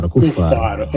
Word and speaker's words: Cuffaro: Abbiamo la consulenza artistica di Marco Mori e poi il prodotto da Gianfranco, correggimi se dico giusto l'accento, è Cuffaro. Ok, sì, di parole suoni che è la mia etichetta, okay.